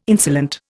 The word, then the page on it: insolent: Indian